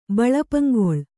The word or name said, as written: ಬಳಪಂಗೊಳ್